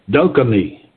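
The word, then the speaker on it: dokimē: I want to take a look at this in the working translation, because the word experience, that's in verse 4 there, is dokimi, D-O-K-I-M, long E.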